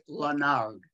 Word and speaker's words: Lenard: Yeah, it's spelled L-E-N-A-R-D. So people look at it and think it's French and want to pronounce it Lenard, but it's not. It's Lenard. But there's no O in it.